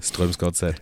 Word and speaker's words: Strømsgodset: Strømsgodset oder so ähnlich, ne? IF Drammen. – Strømsgodset, genau.